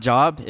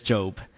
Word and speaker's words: Job: It's not Job. It is spelled like Job, but please don't say Job. It is Job.